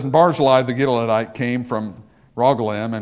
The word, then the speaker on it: Barzillai: because I love this Barzillai. Or Barzillai is how it's actually pronounced.